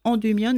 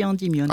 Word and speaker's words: Endymion: On doit dire Endymion d'ailleurs, mais on dit Endymion. – Alors, nous, en France, le Y se prononce I. Mais en fait, il devrait se prononcer U.